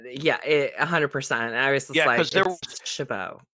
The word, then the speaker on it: Chabot: Okay, but is it Shabbat, like, phonetically spelled, or is it like the French would say Chabot? – I've heard it both. – Because all I heard last night was Shabbat, and part of me was cringing, thinking, I'm pretty sure that you don't pronounce the T.